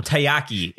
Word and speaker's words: taiyaki: And one of them being, I don't want to mispronounce it because it sounds too close to another Japanese food. – It's called taiyaki. – Yeah, because I keep saying takoyaki, which is the squid balls.